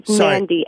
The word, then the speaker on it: Mandy: Mandy. M-A-N-D-Y.